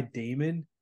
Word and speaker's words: Daemon: Honestly, I don't really know much about it, but it's called My Damon. It's spelled like demon, but with an A after the D, My Damon.